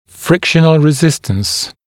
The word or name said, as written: frictional resistance